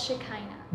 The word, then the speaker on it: Shekinah: but it's Shekinah.